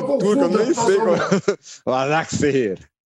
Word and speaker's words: Başakşehir: Eu sou incapaz de falar o nome do time de Istambul, Başakşehir. – Eu falei errado na abertura. Eu falei errado na abertura, é Başakşehir.